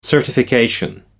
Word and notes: certification: British English

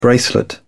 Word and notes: bracelet: British English

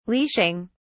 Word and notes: leashing: British English

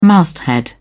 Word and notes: masthead: British English

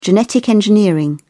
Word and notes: genetic engineering: British English

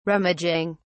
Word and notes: rummaging: British English

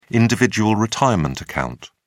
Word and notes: individual retirement account: British English

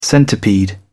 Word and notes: centipede: British English

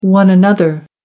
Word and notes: one-another: American English

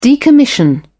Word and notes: decommission: British English

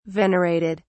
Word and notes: venerated: British English

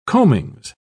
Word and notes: combings: British English

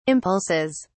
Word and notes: impulses: British English

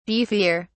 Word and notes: beefier: British English

